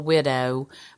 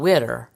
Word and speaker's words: Widder: And it just doesn't sound right to call it Pretty Little Widow, because it's always sung Pretty Little Widder, because it has to rhyme with Going Getter, Pretty Little Widder.